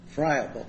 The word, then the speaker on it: friable: If something is friable, is that the way you pronounce that word? – Yes.